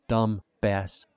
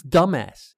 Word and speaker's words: dumbass: She pronounced the B in dumbass again. Dumbass. Knowing she had an idiotic flaw gave me confidence. I almost told her about it. I almost said, it's dumbass, dumbass.